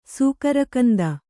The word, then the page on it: ಸೂಕರಗಂಧ: sūkara gandha